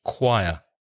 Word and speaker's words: choir: Be careful of the pronunciation because the spelling has nothing to do with the pronunciation. The spelling is C-H-O-I-R, but the pronunciation, choir, choir.